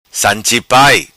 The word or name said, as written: San Chi Pai